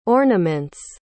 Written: ornament's

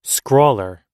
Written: scrawler

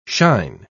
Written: shine

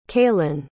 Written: kaylin

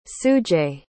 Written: sujay

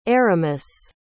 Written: Aramis

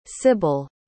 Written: cibil

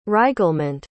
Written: reiglement

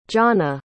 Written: johnna

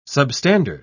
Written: substandard

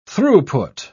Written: throughput

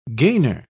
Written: gainer

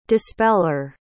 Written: dispeller

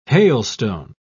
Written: hailstone

